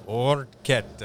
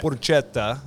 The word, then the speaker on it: porchetta: Porchetta. – Yeah, it's actually going to be here. So, yeah, there's a couple of pronunciations. I said porchetta, all right, and you said porchetta.